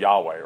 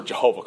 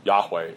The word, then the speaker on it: Yahweh: And that's where Jehovah comes from.